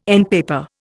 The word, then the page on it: endpaper: Indian